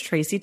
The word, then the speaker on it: Tracy: Here's what I think about people who mispronounce names, because my name is Tracy Thomas. It's very easy. Tracy spelled with an I, and I get tracky sometimes.